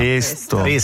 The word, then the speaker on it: pesto: Si pronuncia con la E chiusa in italiano. – Pesto.